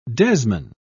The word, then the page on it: desman: British English